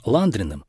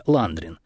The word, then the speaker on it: Ландрин: Конфетки назвали по имени изобретателя Ландрин. Но, ясное дело, не может же что-то хорошее прийти из России. И начали называть конфеты с французским акцентом, с ударением на второй слог — Ландрин.